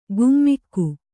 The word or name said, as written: ಘುಮ್ಮಿಕ್ಕು